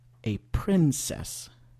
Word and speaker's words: princess: Et dans ce mot, c'est prin ou prin en anglais. Alors, a princess. Parce que si vous aviez dit a princess, ça allait un peu étrange, c'est différent. C'est pas forcément incompréhensible, bien sûr, mais ça sonne un peu bizarre à l'oreille.